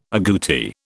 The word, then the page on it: aguti: British English